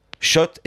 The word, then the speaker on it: Kszczot: Alors, je le prononce Schott parce que je me suis renseigné, mais c'est bien au Scrabble. Hein. K-S-Z-C-Z-O-T.